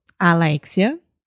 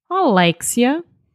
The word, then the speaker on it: Alexia: So, Alexia in Brazilian Portuguese, right? Portuguese people, they say something kind of Alexia. – Yeah. – It's a little bit different, right? – Yeah, they have a closed vowel sound at the beginning.